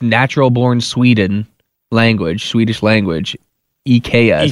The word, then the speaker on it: IKEA: I guess it's actually pronounced IKEA.